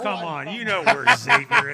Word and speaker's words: Xavier: Hey, at least you pronounced it properly. – Oh, good. Okay. All right. – A lot of guys will call it Xavier, and that drives me nuts.